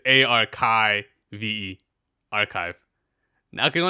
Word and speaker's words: arXiv: Basically, there was a paper that was posted to the archive, spelled ArcSiv, but pronounced archive for some reason.